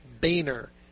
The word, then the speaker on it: Boehner: He was invited by the Speaker of the House, John, how do you pronounce his last name? Boehner, John Boehner.